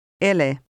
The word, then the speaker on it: ele: In Finnish, the E is always pronounced, even at the end of a word. Listen and repeat number 5, noting the different pronunciation of the E. – Ele.